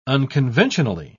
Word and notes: unconventionally: British English

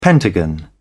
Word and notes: pentagon: British English

